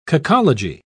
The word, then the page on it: cacology: British English